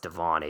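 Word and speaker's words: De'Von: I think it's actually pronounced Devon Achane, but I think it's spelled the same.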